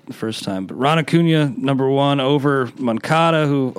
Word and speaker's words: Acuña: You got Ron Acuña number one. I guess it's Acuña. I was mispronouncing it forever, but it makes sense with the accent.